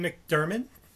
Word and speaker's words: McDiarmid: And then the Emperor, Ian McDiarmid.